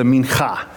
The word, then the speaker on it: mincha: But the first one is called the minhah. Or if you want to get extra Jewish and pronounce it correctly, it's the mincha. So you got to get some throat in there.